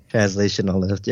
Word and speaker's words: translational lift: I know nothing about transitional lift or even how to pronounce it. – Yeah, me either, apparently. – Apparently. – Translational lift? – Translational lift, yes.